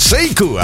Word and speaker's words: Sekou: Seiku, así es como se llama, aunque si lo quieres localizar, que sepas que se escribe Sekou con K.